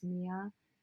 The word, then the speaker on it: Mya: But anyway, you can find me at Mia Rose Miller _ on Instagram, which my name is spelled MYA, but it is Mia.